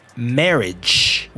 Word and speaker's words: marriage: And this person is married with a D sound at the end. Married. What I'm about to talk about is not married, it is marriage with the j j sound at the end.